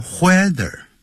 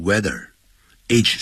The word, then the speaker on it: whether: 那我们就在前面加一个 whether，这个不要念成 whether，要念成 weather，H 是不发音的。